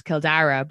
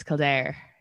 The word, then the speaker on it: Kildare: When Dublin is like Blackpool, or like I'm from Kildare, which is Kildara. But when the Brits pronounce it, it's Kildare.